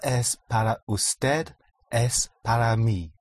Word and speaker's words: usted: Usted, spelled U-S-T-E-D. The D is hardly audible. Usted.